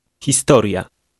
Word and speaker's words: historia: The last two letters are pronounced as one. – Historia.